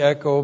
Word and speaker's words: echo: E-C-H long O, echo. Now, literally, echo means to have and to hold or to possess.